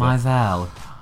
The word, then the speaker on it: Maisel: I never know how to pronounce Maisel. Blaisel, like you.